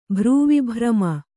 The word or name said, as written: ಭ್ರೂವಿಭ್ರಮ